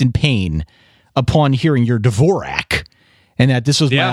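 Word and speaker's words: Dvořák: Dvorak? – That's how I pronounce it. I'm sure I'm wrong. – Antonin Dvorak is rolling over in his grave because it's Antonin Dvorak. – Dvorak.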